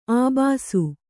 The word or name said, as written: ಅಬ್ಬೆಸ